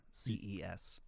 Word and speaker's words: Xerces: And that's spelled with an X. So Xerces.